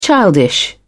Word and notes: childish: British English

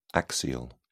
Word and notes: axial: British English

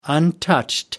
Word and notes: untouched: American English

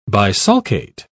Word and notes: bisulcate: British English

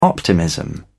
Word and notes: optimism: British English

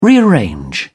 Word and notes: rearrange: British English

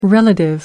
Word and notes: relative: American English

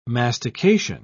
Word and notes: mastication: British English

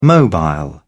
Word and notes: mobile: British English

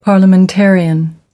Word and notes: parliamentarian: American English

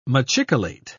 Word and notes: machicolate: British English

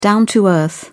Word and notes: down to earth: British English

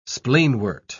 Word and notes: spleenwort: British English

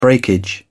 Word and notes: breakage: British English

